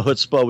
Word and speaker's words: chutzpah: Some of them I find kind of interesting, like chutzpah. And to say it correctly, you got to get a certain amount of spit in there. – Chutzpah. – Yeah, it's like you're spitting on the ground because it's sort of a term of contempt.